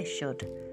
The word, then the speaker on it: should: Se escribe should, pero la pronunciación es muy importante aquí. Se dice should, ¿vale?